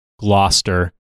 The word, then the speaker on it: Gloucester: At one point, Henry learned his brother, the Duke of Gloucester? I don't know England. I don't know your town names. – It's pronounced Gloucester.